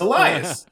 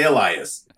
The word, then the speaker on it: Elias: The other thing that bothered me a little bit is one of the boys' names, she kept calling him Elias, but it's spelled Elias. And for some reason, I couldn't get over it. It's Elias. Say Elias.